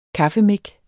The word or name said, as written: kaffemik